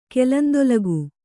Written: ಕೆಲಂದೊಲಗು

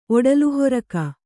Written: ಒಡಲುಹೊರಕ

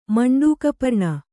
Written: ಮಂಡೂಕಪರ್ಣಿ

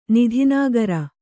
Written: ನಿಧಿನಾಗರ

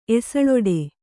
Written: ಎಸಳೊಡೆ

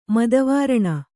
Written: ಮದವಾರಣ

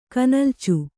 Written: ಕನಲ್ಚು

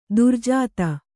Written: ದುರ್ಜಾತ

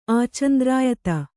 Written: ಆಚಂದ್ರಾಯತ

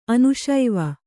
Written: ಅನುಶೈವ

